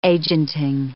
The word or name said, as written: agenting